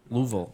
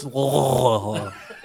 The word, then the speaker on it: Louisville: Louisville. – No, you said it wrong. It's Louisville.